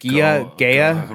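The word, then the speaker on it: GEHA: Yeah, I don't even know how to say it. Gea? – Right. – It's G-E-H-A.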